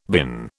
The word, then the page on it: bin: British English